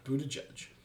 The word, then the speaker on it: Buttigieg: That is not Buttigieg. There's no way that's how you pronounce that. – Buttigieg. That's how you pronounce it.